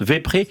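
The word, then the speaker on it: Vesprée: Ça s'appelle Vespré. Ça s'écrit Vespré, V-E-S-P-R-E-E. Ça se prononce Vespré. C'est tiré du mot vêpre.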